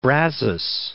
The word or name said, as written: Brazos